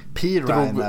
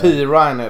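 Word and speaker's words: Perine: running backen LaMichael per, eh, Perrine där eller Perrin. Mm. – Eh, Pirine är väl det. Pirine är det, just det.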